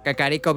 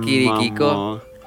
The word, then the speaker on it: Kakariko: Kakariko Village. – Ah, no manches. Pues es lo mismo, nomás que no lo pronuncié bien. – Le dijiste Kirikiko.